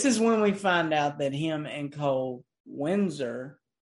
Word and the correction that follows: Wenzel: Did I say it right? – Wenzel.